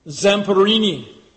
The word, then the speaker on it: Zamperini: I know some of you, I was thinking of this when I read the story of Louis Zamperini, that's how you pronounce it.